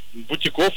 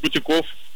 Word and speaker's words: бутик: А потому что оно пришло к нам из французского, в котором, как известно, ударение падает на последний слог. То есть правильно говорить. Так, подождите, вы сказали бутик? Бутиков? – Да, нет, бутик. Бутиков. – А, бутиков, и все. Я тут сам это уже, короче, поплыл. Правильно говорить «бутик» и, соответственно, «бутиков».